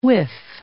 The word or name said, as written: whiff